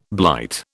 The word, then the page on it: blight: British English